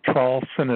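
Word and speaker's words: Traulsen: Yes, it would be my name, which is Hugh, H-U-G-H, the last name Trawlson is spelled T like Tom, R-A-U-L-S-E-N like Nancy, after my name.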